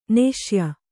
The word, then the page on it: ನಾಸ್ಯೆ: Kannada